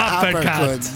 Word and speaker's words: uppercut: Da questo 1-2-1-2 sì. Che ha infilato. – Da questo uppercut, come si dice? – Sì, uppercut. Bravo, so uppercut. Si pronuncia, sì.